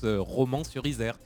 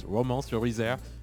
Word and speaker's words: Romans-sur-Isère: on prononce Romans-sur-Isère. Notamment oui. En Australie, à New York, et ils disent Romans-sur-Isère.